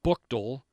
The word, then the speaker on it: Buchtel: Talmadge, that's T-A-L-L-M-A-D-G-E, pronounced Talmadge. Copley, C-O-P-L-E-Y, Copley Township. Buchtel High School, that's B-U-C-H-T-E-L, Buchtel High School, City Barberton. Barberton, we also have Stowe, S-T-O-W.